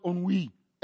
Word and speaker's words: ennui: I had to click the listening because I was going to say ennui. But it says it's pronounced ennui.